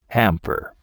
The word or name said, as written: hamper